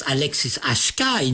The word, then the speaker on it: Alexis HK: The singer-songwriter Alexis HK, pronounced Alexis HK in French, is immediately identifiable through his deep, melancholy voice.